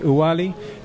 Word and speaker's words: Ouali: So the name of this resort we're at is Owali. Right, Owali Beach Resort. Did I say it correctly? – You did. You have to think French. O-U. So O-U-A-L-I, Owali.